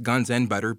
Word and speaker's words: GunsXButter: It's pronounced Guns and Butter, but spelled GunsXButter.clothing.